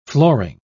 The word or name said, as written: flooring